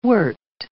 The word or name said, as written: wort